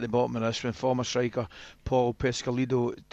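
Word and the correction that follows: Peschisolido: he said... – Pesky Solido. – Yes, it finally happened. Alan corrected someone else on their pronunciation.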